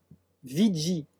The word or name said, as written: viggi